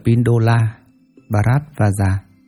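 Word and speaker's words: Pindola Bharadvaja: Ngài Tân Đầu Lư Phả La Đọa, Ấn Độ đọc là Pindola Bharatvaja, chữ Hán là Bất Động, là Lợi Căn.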